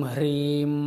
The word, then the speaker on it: Hrim: The Hrim is considered as the root sound symbol or the bija for the Shakti. It's a slightly difficult pronunciation for some people because the sound ha is not there in some of the cultures.